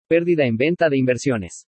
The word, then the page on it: PÉRDIDA EN VENTA DE INVERSIONES: spanish